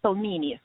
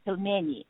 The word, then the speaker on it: pelmeni: So when you say the name of the dish in English, it sounds pelmeni, right? And that's how we all say it. But if you say that in Russian language, it is pronounced pelmeni.